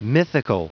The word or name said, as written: mythical